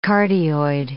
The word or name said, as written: cardioid